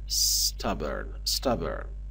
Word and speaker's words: stubborn: عزیزان دقت بکنن، کلماتی که با حرف اس شروع میشن در هنگام تلفظ کردن باید اس رو از حالت ساکن تلفظ کنیم، باید بگیم stubborn. مثلا اینجا stingy، نباید بگیم استینجی، ای نباید اولش بذاریم، اینجا پس میشه stubborn.